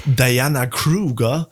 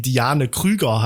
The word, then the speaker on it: Diane Krüger: Zum Beispiel diese Diana Kruger, die viele genau so aussprechen, die aber trotzdem einfach deutsche Vorfahren hat und deswegen Diane Krüger heißt.